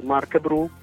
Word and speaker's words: Marcabru: Inoltre ci è piaciuta anche la sonorità del nome stesso, Marcabru, che si pronuncia alla francese con l'accento finale, anche l'accento non si scrive.